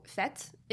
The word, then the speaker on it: fête: So, fête is French for party, and it's spelled F-E-T-E, and there's an accent, not an accent aigu, it's a circumflex. – Sure. – It's like the little triangle above the E, and so it's a fête worse than death.